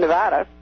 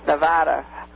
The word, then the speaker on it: Nevada: So I just would I'd like to point out that with the two parties in Nevada, I finally learned how to pronounce Nevada. I used to say, I'm an Easterner, I used to call it Nevada. Nevada.